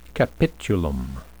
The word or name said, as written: capitulum